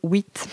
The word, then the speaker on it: Wheat: J'ai envie de dire white à chaque fois, mais je pense que tout le monde. On prononce euh, le, i à la française.